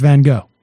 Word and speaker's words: Van Gogh: I think you're fine. It's Van Gogh. – Well, I pronounce it the way it's supposed to be pronounced, but you go, girl.